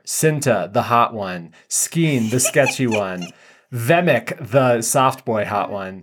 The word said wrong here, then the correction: Nemec: Nemec. It's Nemec.